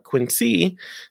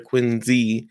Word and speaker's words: Quincy: It's now Quincy, and I learned that it's not Quincy, the way most Americans would pronounce it, but rather Quincy, Z-E.